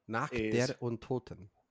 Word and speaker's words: Nacht der Untoten: Nacht der Untoten, or however you pronounce it. – Nacht der Untoten.